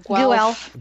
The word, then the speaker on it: Guelph: In the regular season, Nick Suzuki played 59 games, and he amassed 94 points between the Owen Sound and the Guelph Storm, however that's pronounced. – Guelph. – Guelph Storm. – Oh. – And, what? I don't know these things. I look at the word. It says Guelph. It's Guelph.